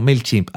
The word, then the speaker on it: MailChimp: MailChimp, ¿cómo se dice esto? No, MailChimp. Ah, qué bien lo dices.